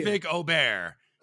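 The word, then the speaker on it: Ludvig Åberg: Wes, I like that you got Ludwig's pronunciation right, though. – Thank you, Wes. Thank you. – Ludwig Ober. Because it's been Aberg, you know, but...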